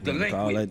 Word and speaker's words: Dlequint: It's pronounced Dlequint, bitch.